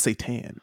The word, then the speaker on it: seitan: It's not spelled the same way. – Are you sure? – S-E-I-T-A-N. That's how you spell it. It's Satan. So it's not S-A-T-A-N. There's an E and an I in there that make up.